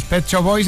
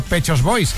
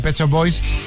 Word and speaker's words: Pet Shop Boys: Y gracias porque hiciste que el Tecnopop de los 80 nos sonara muy familiar, a pesar de que al descubriros aún no sabíamos pronunciar muy bien lo de los Pet Shop Boys, decíamos todo el mundo Pechos Boys.